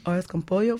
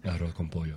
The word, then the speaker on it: arroz con pollo: He said, I'm going to make some arroz con pollo. For, how you say it in Spanish? – Arroz con pollo.